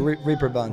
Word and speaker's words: Reeperbahn: Am I saying that right? Reeperbahn? – The Reeperbahn.